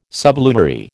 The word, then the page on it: sublunary: American English